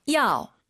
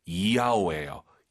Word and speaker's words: 要: YAO 발음할 때 한국인들이 보통 야오 야오 하시는데요. 틀린 발음이에요. Y를요 항상 I로 바꾸세요. 정확히 발음하면요 이야오예요. 야오. 이걸 빨리 발음해볼게요. – 야오. – 예, 절대 야오, 야오 아니에요. 사실은 야오, 이로 시작하는 겁니다.